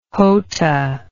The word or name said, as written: jota